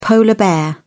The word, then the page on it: polar bear: British English